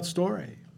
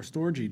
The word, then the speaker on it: storge: What about storge, or storge, depending on how you want to pronounce it in the Greek?